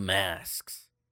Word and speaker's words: masks: The masks. I always add an E between the K and the S in masks. I can't say it right. S-K-S. – Masks. – Yeah, S-K-S really throws me.